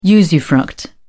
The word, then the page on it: usufruct: British English